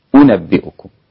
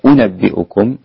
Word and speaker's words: أأنبئكم: طيب أو الهمزة الثانية اللي هي أنبئكم اللي هي المضمومة بيسهلها قالون والإمام أبو جعفر مع الإدخال، إدخال ألف بينهم، فيصير النطق هكذا قل أنبئكم قل أنبئكم. طيب لو ما أدخلتش هيكون النطق عامل إزاي؟ أنبئكم أنبئكم مش أنبئكم، ده اسمه الإدخال. الفرق بين إدخال ألف بين الهمزتين وفرق بين عدم الإدخال. بالإدخال يكون النطق هكذا أنبئكم، بعدم الإدخال يكون النطق هكذا أنبئكم. طيب احنا ألفنا قالون وأبو جعفر بيسهلوا الهمزة الثانية مع الإدخال.